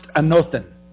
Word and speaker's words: anothen: Now, if we go back and look at what Jesus was saying there about being reborn, back in that original language, that translation of born again is pronounced anothen.